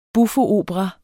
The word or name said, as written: buffoopera